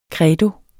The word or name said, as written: CREDOS